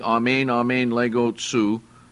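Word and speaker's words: amen, amen, lego, tsu (ἀμὴν ἀμὴν λέγω σοι): Amen, amen, I say to you, and it's pronounced in the Greek, amen, amen, lego, tsu, and we'll see all the way through that that amen, amen, lego is used by Jesus all the way through.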